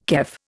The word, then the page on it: kef: Indian